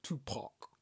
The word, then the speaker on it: Tupac: Let's say his name properly. Pac. Tupac. – Tupac. – Tupac. – Tupac. – Pac. – P-A-C. – It's closer to Pac.